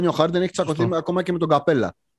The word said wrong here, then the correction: Καπελά: Εγώ θα κρατήσω την ελβετική προφορά. Καπελά, σωστό.